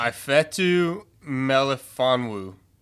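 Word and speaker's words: Ifeatu Melifonwu: Ifetu Melifonwu.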